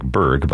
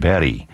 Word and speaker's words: Berg: The composer is Natanael, and his last name looks like Berg, but it's Natanael Berry. Royal Stockholm Philharmonic Orchestra, conducted by Per Boring. And here is music by the composer who has the same last name, pronounced differently, Alban Berg, B-E-R-G, and this is one of his seven early songs, the Liebesode, the Ode to Love, sung by Jesse Norman.